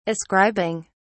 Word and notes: ascribing: British English

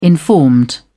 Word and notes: informed: British English

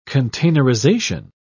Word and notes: containerization: British English

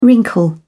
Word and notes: wrinkle: British English

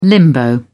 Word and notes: limbo: British English

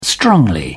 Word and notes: strongly: British English

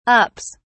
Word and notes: ups: British English

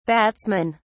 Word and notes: batsmen: British English

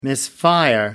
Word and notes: misfire: American English

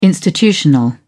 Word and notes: institutional: British English

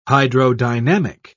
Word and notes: hydrodynamic: British English

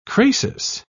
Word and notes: crasis: British English

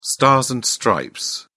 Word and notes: stars and stripes: British English

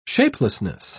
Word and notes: shapelessness: British English

